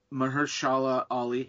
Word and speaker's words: Mahershala Ali: Like, I have no problem with them bringing in Diamondback in the end, although Mahershala Ali,